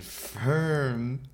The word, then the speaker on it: Phirm: The first song is Rodeohead, and that's by Hard and Firm. – What? It's spelled P-H-I-R-M.